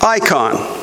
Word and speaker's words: eikon: The word image in the Greek language is spelled E-I-K-O-N, which is pronounced icon.